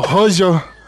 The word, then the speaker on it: rojo: O Poço, ou em espanhol, é o Joyo, é o Royo, não sei como é que fala essa porra. É o Rojo.